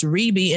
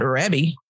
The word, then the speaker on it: Rebbie: And let's be clear, we might not know how to say that, but we do know it's pronounced Rebbie and not Rebbie.